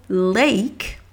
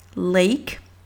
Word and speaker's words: lake: Et c'est définitivement différent du son, de la phonétique, de la prononciation du mot lake, lake, donc L-A-K-E, s'écrit, moi dans cette phonétique francophone, j'écris l e i deux l e lay, lay, k et un K. Lake, lake. Pour qu'en fait on comprenne que le L-A-K-E, c'est pas lac, c'est un, un un A long, le, A long anglais qui est A, A, lake, comme abeille, abeille.